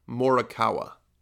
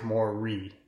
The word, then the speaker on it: Morikawa: And Japanese and like people in Hawaii, they pronounce it A-E-I-O-U. Oh, ooh. So it's, you know, obviously you have Mo, so Mo-ri, so R-I is Mo-ri, not Mora. So there's an I, so Mo-ri, and then you have Kawa.